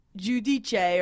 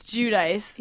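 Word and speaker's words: Giudice: But Teresa, when I interviewed her, I pronounced her name wrong. I said it was Judice or something. And she was like, what? That's not my name. And then it's Judice.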